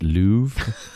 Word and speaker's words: Louvre: You saw, you sort of pronounce the R, but it's very faint at the end. So there's like a it's Louvre. Like there's like a.